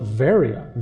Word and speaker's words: Veria: Another point along those lines, they don't call it Berea. They call it Veria, V-E-R-I-A. And so you look up a Greek map, it says Veria.